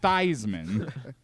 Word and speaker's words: Theismann: Theismann. Because it rhymes with Heisman and maybe improves your chances of winning the trophy.